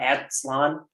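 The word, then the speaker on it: Aztlán: A-Z-T-L-A-N. Is it Aztlan? Aztlan? – Aztlan.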